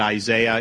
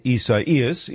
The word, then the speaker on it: Isaias: So, you know, I'm calling it Isaiah. You guys can call it what you want. I know that's not how you pronounce this.